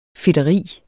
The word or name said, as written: fedteri